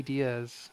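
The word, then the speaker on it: Diez: Yep, I'm on Instagram at it's Danny Diaz, and my last name is spelled D-I-E-Z, E like elephant, Z like zebra. Pretty uncommon spelling, but I gotta remind people because every time I go somewhere, they like spell it D-I-A-Z or something like that.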